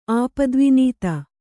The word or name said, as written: ಆಪದ್ವಿನೀತ